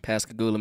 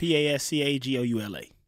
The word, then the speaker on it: Pascagoula: Am I saying that right? Pascagoula? – Mm-hmm. – Pascagoula, Mississippi. – Pascagoula.